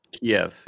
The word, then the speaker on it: Kiev: Is it Kiev? Kiev? – Kiev, yeah. – Kiev. Kiev, you got it.